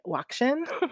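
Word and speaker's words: WOCSHN: So just so folks know, as I talk, the acronym, we pronounce it WACSHN. So I might just say WACSHN instead of the whole acronym.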